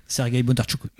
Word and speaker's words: Sergueï Bondartchouk: Sergei Bondarchuk.